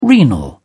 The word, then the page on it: renal: British English